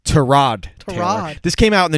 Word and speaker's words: Tyrod: His name is pronounced Tyrod Taylor.